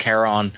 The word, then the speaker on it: Charon: Hal and the others are on the moon Charon, Charon. It's pronounced Charon, which is the only known moon of Jupiter in 1985.